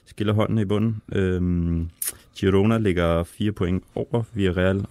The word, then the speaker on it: Girona: Girona via Real.